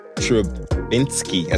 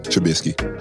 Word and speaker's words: Trubisky: Shout out to Mitch Trubisky. I said it right? – No, Trubisky.